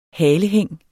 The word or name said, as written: halehæng